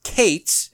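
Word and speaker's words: Kates (קץ): The first is Q-E-S, Kates, is how that's pronounced. The second is yom, Y-O-M.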